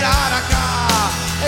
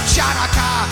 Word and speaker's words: Hanukkah: It's time to celebrate Hanukkah. It's not pronounced Chanaka. The C is silent in Hanukkah.